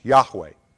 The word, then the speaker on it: Yahweh: And the name, probably the best way to pronounce it, Most scholars think it's to be pronounced Yahweh.